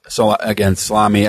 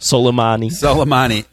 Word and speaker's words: Soleimani: I mean, let's first of all, this Salami, I can't pronounce his name. – Soleimani.